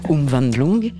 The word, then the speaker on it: Umwandlung: Umwandlung. – Voilà, Umwandlung.